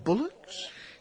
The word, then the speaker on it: bulwarks: Bullocks? That's spelt bulwarks. But that's pronounced bullocks.